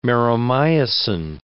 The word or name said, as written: meromyosin